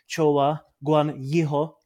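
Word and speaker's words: Zhou Guanyu: hodně báli o Chowa Guan Jiho, jak jsem byl poučen, že se to má správně vyslovovat, ale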